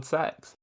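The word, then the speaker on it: CeX: I don't think that it's pronounced sex. I think it's C-E-X. – No, it's they pronounce it sex on the adverts. – True story. – It's definitely called sex.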